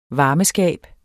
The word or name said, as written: varmeskab